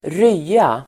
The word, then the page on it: rya: [²r'y:a]